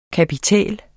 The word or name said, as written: kapitæl